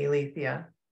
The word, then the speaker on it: Eileithyia: So my connection with her, and I use a sort of generic ancient Greek pronunciation, which is Eileithyia, and the modern Greek pronunciation would be Eileithyia.